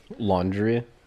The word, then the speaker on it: Laundrie: Laundrie? – Yeah, Laundrie. Okay. Spelled like laundry, like dirty clothes, but with an I-E instead of a Y.